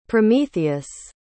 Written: prometheus

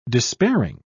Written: despairing

